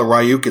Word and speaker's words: Ryuk: So like one good example, there's a really bad strain of ransomware that was hitting a lot of healthcare organizations called, I don't know how you pronounce it. I call it Ryuk. It's R-Y-U-K.